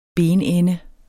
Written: benende

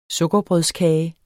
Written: sukkerbrødskage

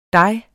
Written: dig

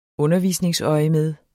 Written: undervisningsøjemed

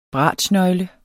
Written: bratschnøgle

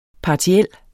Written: PARTIELT

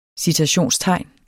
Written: citationstegn

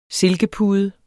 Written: silkepude

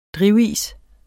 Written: drivis

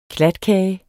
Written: klatkage

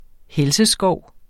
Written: helseskov

